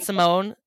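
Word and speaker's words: Symoné: Raven What's Simone is not Simone. It's her name is, it's pronounced Simone.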